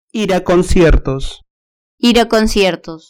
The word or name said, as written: ir a conciertos